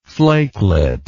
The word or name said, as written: flakelet